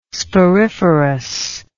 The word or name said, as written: sporiferous